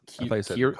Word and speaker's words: Keurig: How do you say that? – It's Keurig. Coffee machine.